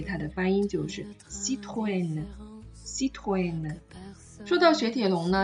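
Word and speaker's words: Citroën: 所以在这里，我们应该把它读成 Citroen，Citroen。这个就是雪铁龙的法语的发音了。那我们不能把它读成 Citroen 啊，因为我们知道 a n 在法语当中是一个字母组合。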